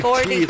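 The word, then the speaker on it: Caillat: Calais. Almost spelled like peanut butter parfait.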